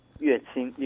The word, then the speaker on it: 乐清: 我们的神父呢，他是浙江的，对，那个字要怎么念？乐还是乐清市？ – 乐清，音乐的乐。 – 音乐的乐啊，难怪哈，难怪神父这个地方呢充满音乐，还充满青年人。那浙江乐清市人，那目前是乐清堂的本堂神父，还有监管那个永嘉堂。